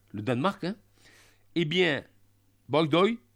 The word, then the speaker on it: Bygdøy: Bygdøy. Je le prononce à la norvégienne. Hein. – Bygdøy.